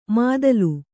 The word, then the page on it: ಮಾದಲು: mādalu; Kannada